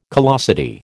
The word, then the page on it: callosity: American English